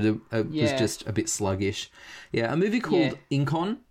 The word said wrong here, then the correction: Inchon: I think I'm pronouncing it properly, was... – Inchon. – Inchon? Yep, so it's phonetic.